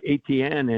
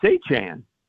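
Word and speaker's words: Etienne: And in his first press conference, you know, he says it's not ATN anymore. And I live not far away from a family of ATNs. They spell it the exact same way, said it that way. And he said, it's Achan.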